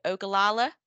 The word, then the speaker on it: Ogallala: Let me see if I can pronounce it. Ogallala aquifer. Yes. Nailed it.